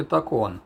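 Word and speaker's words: tacón: Позже я спросил у Эрика, поскольку язык он знал гораздо лучше моего, что значит по-испански «такон» с ударением на втором слоге.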